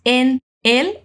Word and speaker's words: en él: Cuando decimos en él, unimos la N a la I. En él. En él.